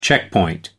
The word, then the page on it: checkpoint: American English